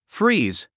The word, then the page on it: freeze: fríːz; フリーズ